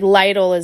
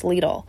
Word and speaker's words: Lidl: And I was like, it's just like Lidl is not correct. No, it's pronounced Lidl.